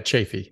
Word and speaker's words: Chaffee: Pretty close. Yeah, Chaffee. Yeah.